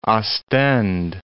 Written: Ostend

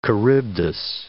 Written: Charybdis

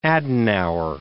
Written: Adenauer

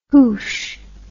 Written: hoosh

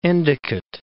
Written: Endicott Mountains

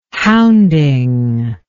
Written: hounding

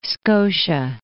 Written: Scotia

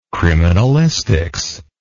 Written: criminalistics

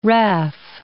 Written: raff

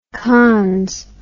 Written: cons

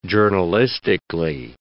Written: journalistically

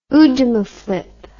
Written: oojamaflip

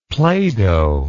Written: playdoh

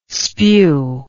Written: spue

